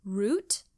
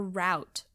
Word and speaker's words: route: Okay, it says you can say root, rhymes with boot, or route, rhymes with about.